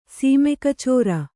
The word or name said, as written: ಸೀಮೆಕಚೋರ